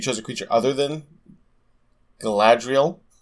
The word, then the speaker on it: Galadriel: Apparently it's Galadriel of Lothlorien. – Sure. – Yeah, I've always pronounced it as a kid, like, because I read the books before I did anything else. And so I've pronounced it Galadriel, which... – Yeah, that's wrong.